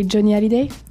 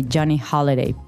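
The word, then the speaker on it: Johnny Hallyday: We say Johnny Holiday. – Johnny Holiday. Remember that you don't pronounce the H. So, but okay, if you're speaking English, you would say Johnny Holiday.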